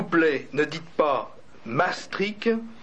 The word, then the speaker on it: Maastricht: Tel qu'on dit Maastricht à Maastricht, parce que dans, au nord des Pays-Bas, la prononciation est beaucoup plus gutturale.